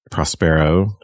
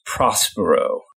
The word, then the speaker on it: Prospero: So the play is about Prospero, who was the one-time Duke of Milan, and was... – I think it's usually pronounced Prospero.